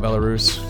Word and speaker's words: Belarus: Oh, Belarus. Is that how you say it? Ooh, Belarus. I like Belarus. – I like Belarus. Belarus. You should go with that. It's cool. It's like Polaris. Belarus.